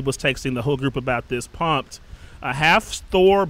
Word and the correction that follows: Hafþór: Bjornsson, I probably butchered his name. – You did, Hafþór. – Hafþór, yeah. Bjornsson, the Mountain, a.k.a.